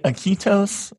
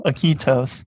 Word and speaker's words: Iquitos: So once you, is it Iquitos? Am I pronouncing that correctly? – As correctly as I can, at least, anyway. Yeah.